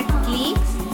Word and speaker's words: Clicx: como familia Clics, familia creyendo lo imposible, Campos Jalapa, pero se escribe C-L-I-C-X.